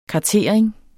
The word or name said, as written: kartering